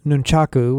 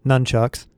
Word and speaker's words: nunchaku: Rather than nunchaku, which is often pronounced nunchucks, the manual recommends carrying a pencil for taking notes and marking buildings.